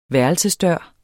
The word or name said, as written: værelsesdør